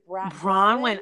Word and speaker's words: Braunwyn: So that's why she's becoming besties with, how do you pronounce her name? Is it Bronwyn? Oh, I love her. I love Bronwyn.